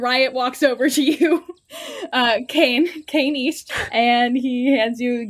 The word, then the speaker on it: Kanye: Is there a Kanye East here? I'm looking for a Kanye East. – It's pronounced Kane. – What? – It's Kane. – What? – Yeah, like Dwayne, but like with a K.